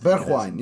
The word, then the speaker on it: Bergwijn: Mourinho, they signed Steven Bergwijn, I think. – Bergwijn, yeah. – It's a Dutch pronunciation. – A very Dutch pronunciation.